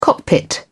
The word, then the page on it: cockpit: British English